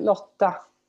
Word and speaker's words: Lotta: In Swedish, is it Lotta? – Lotta. It's different to Danish.